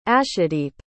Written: ashadeep

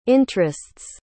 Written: interest's

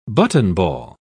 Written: buttonball

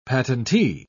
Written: patentee

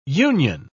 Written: union

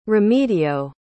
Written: remedio

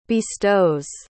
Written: bestows

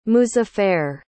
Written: muzaffer

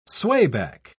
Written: swayback

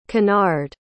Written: conard

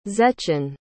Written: zechin

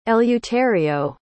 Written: eleuterio

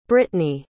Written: brittany

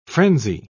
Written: frenzy

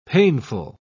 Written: painful

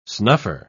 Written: snuffer

